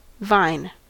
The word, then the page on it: vine: American English